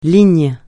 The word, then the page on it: ligne: francais